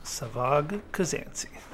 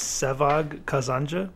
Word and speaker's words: Sevag Kazanci: The real way to say my name is Sevag Kazanca, but that's like the Turkish way and Armenian way to say it. And when I was a kid, I never pronounced it that way because it was so hard to. All my teachers would just say Sevag Kazanci.